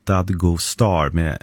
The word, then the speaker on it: Dadgostar: Efternamnet Dadgostar uttalas ungefär Dadgostar med betoning på första och sista stavelsen.